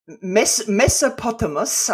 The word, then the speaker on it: Mesopotamos: Mesopotamos, I should just say, that rhymes with hippopotamus.